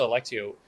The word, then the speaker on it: Alectio: I want to talk a little bit more about Alectio, and I hope I've been pronouncing it right. It's Alectio, right? – Perfectly right. – In regards to Alectio,